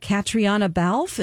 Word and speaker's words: Catriona Balfe: And then the lovely lady, Catriona Balfe, is that how we say her name? She's from that time travel show? – I've never had to say that out loud before, so I agree with you. Yes.